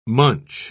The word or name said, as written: munch